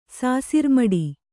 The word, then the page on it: ಸಾಸಿರ್ಮಡಿ: sāsirmaḍi